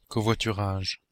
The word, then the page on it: covoiturage: /kɔ.vwa.ty.ʁaʒ/